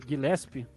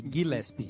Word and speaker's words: Gillespie: Gillespie? Dizzy Gillespie. Gillespie.